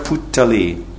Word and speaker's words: puttali: This last verse, he says, Se liki madan gopal e likai kashtera puttali jena kuhake nachai. Puttali. That's interesting. In Bengali we say, this is puttali, which means doll. Sometimes I say putkutli, but it's actually kutputli.